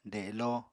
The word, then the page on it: delo: apatani